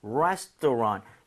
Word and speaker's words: restaurant: Restaurant. 여기 R-A-N-T를 랑트도 아니고 랑도 아니라 살짝 런에 가깝게 아. – 발음이 조금 더 괜찮습니다.